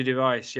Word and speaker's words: de Wijs: Is it Dever? – Jordy de Wijs, yeah, he's been uh, injured.